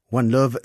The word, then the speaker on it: Wanlov: One Love s'écrit W-A-N-L-O-V et sonne à l'oreille comme l'expression anglaise One Love, un amour.